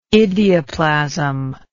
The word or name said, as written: idioplasm